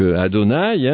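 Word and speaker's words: Adonaï: le tétragramme que l'on prononce donc Adonai, hein, et c'est un récit beaucoup plus sombre.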